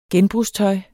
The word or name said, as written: genbrugstøj